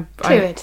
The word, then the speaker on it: Clwyd: It was also at Theatre Clwyd in Wales. – How it do, was. How yes. do we pronounce that? I, I, Clwyd, Theatre okay, Clwyd. That's good.